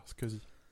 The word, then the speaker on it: SCSI: et c'est comme ça qu'il faudrait prononcer. – Ah ouais Ça va tellement plus vite en plus à dire, scusi.